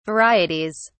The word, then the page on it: varieties: British English